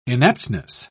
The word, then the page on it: inaptness: British English